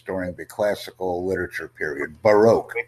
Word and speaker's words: Baroque: Baroque. Please say Baroque. This is the correct pronunciation of this word.